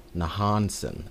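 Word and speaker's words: Nohansen: Daphnis Nahasen Hyrule. Nahansen. I said that wrong.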